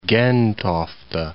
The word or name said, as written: Gentofte